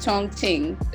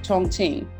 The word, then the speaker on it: Chongqing: You are in Chongqing. Did I say that correctly? – Close, very close. It's Chongqing. The Q is like a chi, ch sound. Chongqing, China.